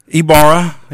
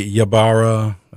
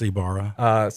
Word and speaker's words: Ybarra: Ybarra, yeah. Yeah, a lot of people say Ybarra, Ybarra, but it's Ybarra.